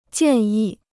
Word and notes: 建议: jiàn yì

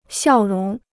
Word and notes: 笑容: xiào róng

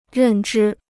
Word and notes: 认知: rèn zhī

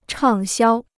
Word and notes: 畅销: chàng xiāo

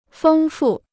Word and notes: 丰富: fēng fù

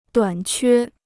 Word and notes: 短缺: duǎn quē

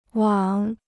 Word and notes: 往: wǎng

